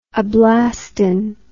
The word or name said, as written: ablastin